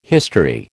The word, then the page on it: history: American English